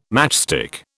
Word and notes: matchstick: British English

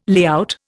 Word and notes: layout: Indian

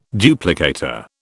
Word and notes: duplicator: British English

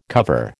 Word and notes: cover: American English

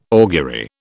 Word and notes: augury: British English

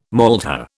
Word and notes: maltha: British English

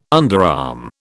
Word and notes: underarm: British English

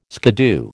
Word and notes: skiddoo: American English

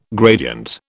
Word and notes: gradient: British English

